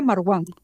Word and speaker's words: Marwan: Y Marwan, a ver, ¿en qué quedamos que se tenía que pronunciar su nombre? – Creo que era Marwan. – Marwan.